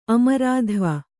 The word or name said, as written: ಅಮರಾಧ್ವ